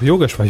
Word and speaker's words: Yogeshwar: Yogeshwar, äh, Yogeshwar, doch Yogeshwar, ich war gerade kurz irritiert, ob er so ausgesprochen wird, macht kein Quarks mehr und mehr Auswahl ZDF-Sender in der 7 TV App.